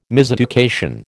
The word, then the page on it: miseducation: American English